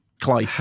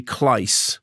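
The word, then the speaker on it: Claes: Is it Kleiss Bang? – Kleiss. – How would you pronounce that? – Well, apparently Kleiss.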